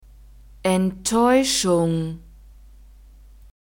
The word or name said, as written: enttaeuschung